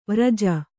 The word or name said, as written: ವಿರಜ